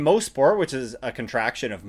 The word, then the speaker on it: Mosport: And yes, indeed, it is pronounced Mosport, not Mosport.